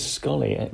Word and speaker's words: Sxollie: So it is a South African company called Scholly. – It is Scholly. – With an X. – S-X-O-L-L-I-E.